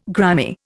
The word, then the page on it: grimy: Indian